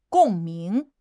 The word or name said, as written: 共鸣